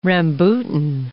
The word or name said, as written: rambutan